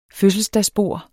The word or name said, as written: fødselsdagsbord